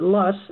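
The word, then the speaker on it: Loess: And much of the state is covered by what everyone here is a German word, but we pronounce it Luss, and everyone's heard of the Luss Hills out in western Iowa, where it's very thick, 250 feet or more.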